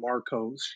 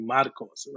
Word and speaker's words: Marcos: Your mother didn't name you Marcos. She named you Marcos, right? And that resonates. But at the same time, like, I also just view it as like, okay, that's how you say my name in English versus how you say it in Spanish, you know? And I think both are valid. I love it. As long as it's not Marcus, Marco, Mark.